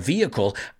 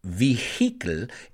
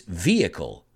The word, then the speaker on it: vehicle: I know the word crankshaft, but if you asked me to point it out on a vehicle, I don't think I could. Y cuidado con la pronunciación. It's not vehicle, it's vehicle. Vehicle. The H is relatively silent. Vehicle.